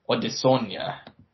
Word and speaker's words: Adesanya: In the main event, Alex Paheya taking on Israel Adesanya. Yeah, I'm pretty sure that's how you pronounce it.